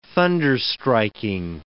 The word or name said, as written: thunderstriking